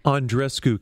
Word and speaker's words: Andreescu: Is it Bianca Andreescu or Bianca Andreescu? I've heard it pronounced both ways. – Andreescu, is the correct pronunciation. That's actually straight from the horse's mouth. When I've interviewed Bianca a couple times, that's how she calls herself. So Andreescu is the correct one.